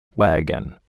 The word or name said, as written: wagon